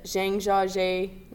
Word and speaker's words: Zhangjiajie: We went to the Zhangjiajie National Forest.